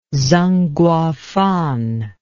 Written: zeng guofan

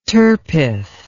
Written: turpeth

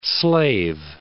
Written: Great Slave Lake

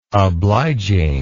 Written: obliging

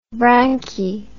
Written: branchi

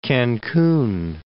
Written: Cancun

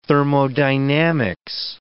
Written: thermodynamics

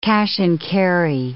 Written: cash-and-carry

